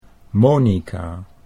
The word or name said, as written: Monika